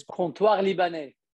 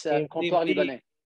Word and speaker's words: Comptoir Libanais: That's where the canteen of Lebanon, though you will read it as the name of the restaurant, is Comptoir Libanais. It's a very difficult word to pronounce. It's French name. Yeah, it's Comptoir Libanais.